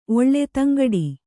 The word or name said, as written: ಧಿಂಕಿಡು